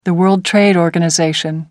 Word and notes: world trade organization: American English

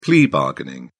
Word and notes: plea-bargain: British English

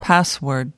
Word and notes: password: American English

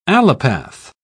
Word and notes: allopath: British English

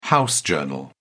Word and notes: house organ: British English

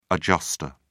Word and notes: adjustor: British English

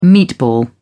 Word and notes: meatball: British English

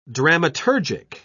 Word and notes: dramaturgic: British English

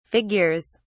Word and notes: figures: British English

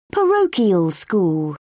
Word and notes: parochial school: British English

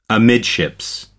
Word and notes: amidships: American English

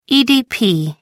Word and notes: EDP: American English

